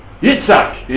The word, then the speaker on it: Yitzhak: Yitzhak is the Hebrew way of pronouncing it.